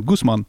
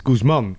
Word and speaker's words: Guzman: Ik ben nog steeds een beetje aan het bekomen dat je Guzman moet zeggen in plaats van Guzman. Het is Guzman. Ja, de, klemtoon ligt op de man.